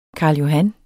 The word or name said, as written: karl